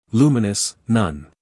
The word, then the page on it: luminous: ˈluːmɪnəs